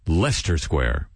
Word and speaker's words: Leicester: And I'm broadcasting to you from just above a square in pretty much in the center of London, Leicester Square. Don't even think it's spelled that way. Think of Worcester sauce, and then, you know, do the rest.